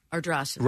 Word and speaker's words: Ardrossan: And it changed in tone, but it's pronounced Ardrossan? – Ardrossan.